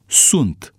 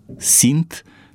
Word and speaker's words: sunt: De aceea mă și întrebam cu altă ocazie, de ce, din moment ce limba română a evoluat din latina populară, a impus Academia rostirea verbului a fi la persoana întâia singular și a treia plural, sunt, ca în latina clasică. Doar ne este clar că strămoșii noștri au ales pronunția din latina vulgară, sint, care l-a dat pe sunt, eu sunt, ei sunt, și nu pe sunt din latina cultă.